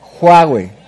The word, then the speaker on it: Huawei: Y a Highway. Siempre tengo duda con la pronunciación, pero está bien, Highway. Bueno, Huawei.